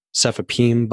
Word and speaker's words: cefepime: So we can probably go back to just like using the basic rhyming scheme for the last couple of letters, which is P-I-M-E, so cefepime, but you could pronounce it pime. And if you mispronounce it that way, then you could have lime, dime, something like that, where you might have trouble explaining that to someone else.